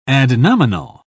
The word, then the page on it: adnominal: British English